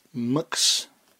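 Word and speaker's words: Mx: M.X. Pronounced Mux.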